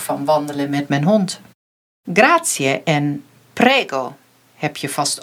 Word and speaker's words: grazie: Je schrijft G-R-A-Z-I-E. Maar in het Italiaans spreek je de I en de E niet als een I uit zoals in het Nederlands, maar het blijven twee losse klinkers. Dus je zegt grazie.